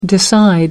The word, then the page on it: decide: American English